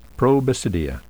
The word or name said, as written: probasidia